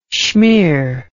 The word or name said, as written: shmear